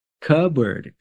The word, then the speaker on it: cupboard: This word also has a silent letter. You can try to say it again. – Cupboard. – Yep. Cupboard. Cupboard. The P is silent in this word.